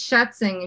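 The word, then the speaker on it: Schätzing: Nah, it's Schatzing, and you got it. I'm German, and you pronounced that correctly.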